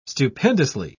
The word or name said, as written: stupendously